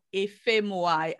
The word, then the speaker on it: Ifemuai: So where they can, where, please give us your I am Eloho Ifemuai across all platforms, and my surname is spelled Echo Freddy Echo Mother Union Alpha India.